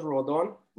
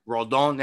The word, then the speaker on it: Rodon: They did go get Rendon, um, or is that how you say it? Yeah, oh. they got Carlos Rodon. Rodon. Yeah, see, you say yeah, it better.